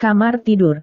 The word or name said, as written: kamar tidur